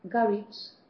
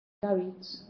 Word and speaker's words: garage: Or garage as well. Sorry. Garage. See? So in the word garage, you've got the different sounds of G.